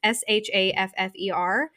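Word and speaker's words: Shaffer: I read The Wishing Game by Meg, I think it is pronounced Schaefer. It's Schaffer.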